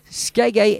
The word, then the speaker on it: Skeggy: Skeggy is spelt like reggae, okay?